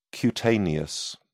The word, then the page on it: cutaneous: British English